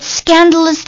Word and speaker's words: scandalous: It's scandalous that you still haven't been paid. 诶，纠正一下啊，这个 scandalous 这个 A 也是要发音的，稍等一下啊。